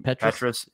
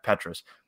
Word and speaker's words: Petras: Petras. – Yeah, Petras.